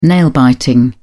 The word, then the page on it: nail biting: British English